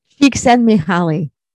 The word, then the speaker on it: Csikszentmihalyi: Csikszentmihalyi.